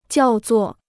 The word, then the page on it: 叫作: jiào zuò